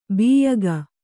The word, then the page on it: ಬಿಯ್ಯಗ: biyyaga